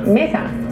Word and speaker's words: Mesa: Hubo uno que en Twitter le pidió que le enseñen a pronunciar correctamente los nombres de las ciudades hay un suburbio que se llama Mesa y, literalmente se escribe Mesa, M-E-S-A, pero aquí no le dicen Mesa, aquí le dicen Mesa.